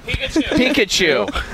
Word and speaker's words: Pikachu: Am I saying that right? – Pikachu.